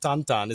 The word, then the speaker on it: Tintin: And we looked it up, and it turns out Tintin is actually pronounced Tantan is the correct pronunciation.